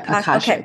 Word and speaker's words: Akashic: Yeah, Akashic.